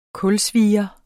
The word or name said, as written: kulsvier